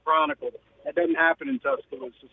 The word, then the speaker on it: Tuscaloosa: You are a way better sports professional than I am, but I believe it's pronounced Tuscaloosa. – Oh, my God. – I believe you're hitting the emphasis a little bit different. Tuscaloosa.